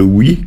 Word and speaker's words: oc: C'est la conjugaison du verbe « être » avec le « oui » qui se dit « o » en occitan et qui s'écrit « oc » si on veut.